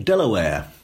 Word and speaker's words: Delaware: And one of the clues is an American clue, as you probably read in the book, that the little state called Delaware tells us how one barony, ancient medieval right. barony, was pronounced. So on the page, it's D-E-L-A-W-A-R-R. – Ah, right. – But Delaware is right. how they pronounce it.